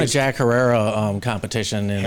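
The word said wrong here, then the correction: Herer: Herrera. Yeah. Say it right, dude. Terror. It's like terror.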